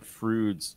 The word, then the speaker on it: Froude: Froude's James Anthony Froude, pronounced the way Bill and Ted uh, pronounced Freud, but spelled F R O U D E.